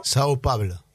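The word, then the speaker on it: São Paulo: Sao Paulo.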